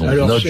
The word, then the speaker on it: Nagy: Nagy, en français on écrit Nagy, N-A-G-Y, mais ça se prononce Nagy, je crois, en hongrois. Alors, on prononce son nom Nagy, en hongrois, ça veut dire grand, gros.